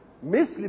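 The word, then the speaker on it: مِثْل: مثل بكسر الميم وسكون الساء مش كده، ومثل بفتح الميم وفتح الساء.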